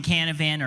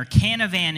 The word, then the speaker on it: Canavan: Well, my name is Justin Canavan, or Canavan in Japan. It rhymes. It's easy to remember.